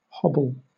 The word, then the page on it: hobble: British English